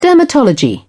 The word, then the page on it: dermatology: British English